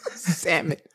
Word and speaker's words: salmon: You don't pronounce the L. It's salmon. – Salmon.